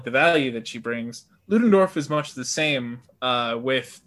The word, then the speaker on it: Ludendorff: And Ludendorff, you know, is it Ludendorff? Am I pronouncing correctly? – Ludendorff, yeah.